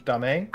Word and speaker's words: dame: D-A-M-E, I don't know. How do you pronounce D-A-M-E in French? Dame? – I made Google Dame.